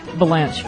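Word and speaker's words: Vilanch: How do you pronounce this guy's name? Bruce Villeneuve? Villeneuve? – Valanche.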